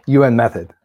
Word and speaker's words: UN method: The UN meth? – not meth, but UN method.